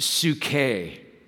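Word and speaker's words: suke (ψυχή): You might say sucky life. But that's not how you pronounce it. Some of you are like, well, that's how my life feels right now. And we want to change that for you. But it's actually pronounced suke.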